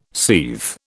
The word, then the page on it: seethe: British English